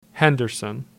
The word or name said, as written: Henderson